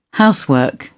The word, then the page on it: housework: British English